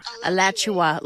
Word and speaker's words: Alachua: Alachua, like Joshua, but Alachua.